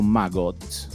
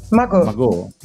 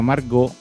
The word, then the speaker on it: Magot: Album pubblicati fino ad oggi, mi scuso sulla pronuncia, è francese, Magot o Magot? – Magot. – Magot. Pensava Margot, perché memoria di Lupin, invece è Magot.